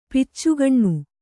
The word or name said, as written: ಪಿಚ್ಚುಗಣ್ಣು